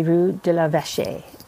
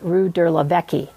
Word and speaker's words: Rue de la Vache: Also, you know, for instance, like, Google will say Rue de la Vache, and which is the correct pronunciation, and Apple will say Rue de la Vecchie or Levesque.